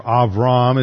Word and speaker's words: Avram: This takes place subsequent to Abram, or Avram as it would be pronounced in the Hebrew, Avram's victory over the four kings that came in and invaded the land.